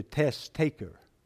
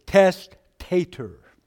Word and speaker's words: testator: Some people taking notes thought I said the test taker. So I was not very clear in the pronunciation. It is testator.